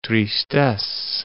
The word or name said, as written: tristesse